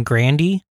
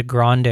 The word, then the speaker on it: Grande: Well, it's like that Ariana Grande, because her family pronounces the name Grandy. – Yeah. – But they chose to, her brother's like, oh, when you're a musician, you should change it to Grande, and it sounds more traditional.